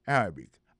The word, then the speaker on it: Arabic: Prononciation correcte là, c'est Arabic.